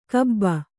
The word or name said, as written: ಕಬ್ಬ